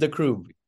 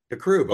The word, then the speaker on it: Dakroub: I am your guest host, and today our guest is estate planning attorney, Hamid Dakroub. Did I pronounce that right? – Hamid Dakroub. – Dakroub, okay.